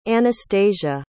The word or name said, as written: Anastasia